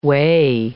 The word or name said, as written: weigh